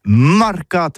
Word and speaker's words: mercat: Eh bien, d'ici à tout, mais le mercat, de l'Uni ou le mercat des rebelles, mais en gascogne toulousain, c'est prononcé sur les marcats, avec un A marcat. En gascogne toulousaine, bah, du côté par exemple de l'île des Baches, c'est l'île Jourdain, pour parler du marché, vous prononcez les marcats.